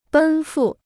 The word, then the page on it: 奔赴: bēn fù